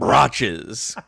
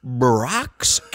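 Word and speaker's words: Brach's: A company called, I really wish you would have said Brotches, because it's spelled C-H. That would have been funny. A company called Brock's Candy has come out with a candy corn.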